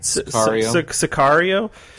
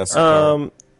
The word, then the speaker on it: Sicario: movie, Cicero, or is that how you say it? – Sicario. Yeah, Sicario.